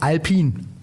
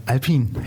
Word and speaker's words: Alpine: Die Marke spricht man aus Alpine. Geschrieben Alpine, nee, aber das E ist stumm. Also wir sind hier bei Alpine.